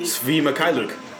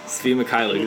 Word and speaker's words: Svi Mykhailiuk: Svi Mykhailiuk. – Svi Mykhailiuk.